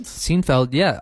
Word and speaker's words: Seinfeld: And if you haven't seen the show, I hope I'm pronouncing it right. It's called Seinfeld? – Seinfeld, yeah. Also on Netflix.